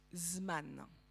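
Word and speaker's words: zman (זמן): Le temps en hébreu, ça se dit euh, zman. Zman, c'est un mot euh, fascinant en hébreu parce qu'il s'écrit zeman.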